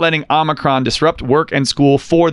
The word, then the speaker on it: Omicron: Dude, so Omicron, the long O is not right. It's just odd. Just make an AH, Omicron.